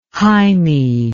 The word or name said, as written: hymie